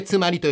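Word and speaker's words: 妻有: つまり、えー、妻がある奥さんがあると書いてつまりと読みますが越後つまり第一の芸術祭の里についてご紹介しましょう新潟県十日町市と津南町からなる越後つまり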